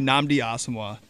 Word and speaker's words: Nnamdi Asomugha: Give me Nnamdi Asomugha.